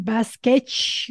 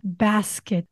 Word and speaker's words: basket: It's like bass bass and then cat basket basket